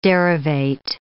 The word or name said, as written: derivate